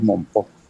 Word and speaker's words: Mompox: Bueno, pertinente pregunta, porque el Festival de Jazz, Festival Internacional de Jazz de Mompox, se escribe con S o con X, pero todos decimos Mompox con J, convoca a artistas de mucha importancia a nivel de este género.